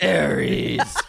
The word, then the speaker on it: Ares: That's the correct way to say it. Ares.